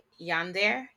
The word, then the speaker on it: yandere: So she becomes a yandere. How do you say that? – Yandere. – There we go.